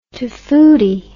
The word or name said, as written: tofutti